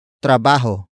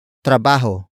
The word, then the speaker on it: trabaho: Trabajo. Trabajo. Trabajo is spelled as T-R-A-B-A-H-O.